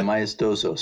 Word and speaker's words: maestoso: Yeah, maestoso.